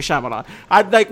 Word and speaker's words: Shyamalan: Night Shyamalan, I know how to say his name is Shyamalan.